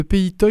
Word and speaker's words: Pays Toy: Vous vouliez aussi attirer notre attention sur le Pays Toy. Peut-être vous pouvez présenter d'abord le Pays Toy, je ne sais pas si je le prononce correctement, où est-ce ? – Vous le prononcez très bien, le Pays Toy.